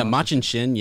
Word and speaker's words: Machin Shin: We're in the ways and we are being kind of chased by the, Machin Shin. I hope I'm pronouncing that right. You know, like Machin Shin. – Yeah, Machin Shin.